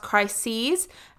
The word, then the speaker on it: crises: And if we even just start reading the article, the word crisis is repeated multiple times in plural, which is pronounced slightly differently, and it's quite strange. It's pronounced crises.